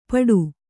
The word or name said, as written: ಪಟು